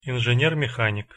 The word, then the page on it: inzener-mehanik: Russian